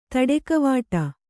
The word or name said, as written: ತಾಡಿಕವಡೆ